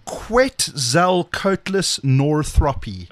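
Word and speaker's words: Quetzalcoatlus northropi: It's a Quetzalcoatlus Northropy. Quetzalcoatlus Northropy.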